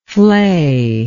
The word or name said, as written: flayer